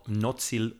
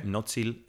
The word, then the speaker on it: Mnozil: The big ensemble is called Nozil Brass. – Okay. – Which is spelled MNOZIL. – Okay. – Nozil and brass, the English word brass.